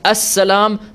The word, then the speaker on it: as-salam: As-salam, and I'm going to emphasize as-salam, not salam. And we'll discuss why, but I really want to emphasize that as-salam is the way we pronounce this.